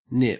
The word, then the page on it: nip: Australian English